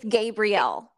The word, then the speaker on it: Gabrielle: It's it's it's Gabrielle.